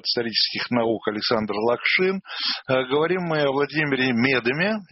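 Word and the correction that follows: Медеме: Я исправляюсь с ударением. Вот о Владимире Медыме, одном из лидеров еврейского рабочего движения.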